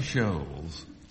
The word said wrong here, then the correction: Scholes: Scholes. – Describes. – Scholes, is it? Scholes, yeah.